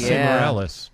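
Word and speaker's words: Esai Morales: Oh, and Isai Morales. Is that how you say his name? – Yes, Isai Morales.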